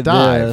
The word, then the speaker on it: DIIV: I say it Dive because it used to be called Dive. So okay. – That's what I say. – It's spelled D-I-I-V.